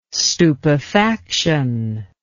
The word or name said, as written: stupefaction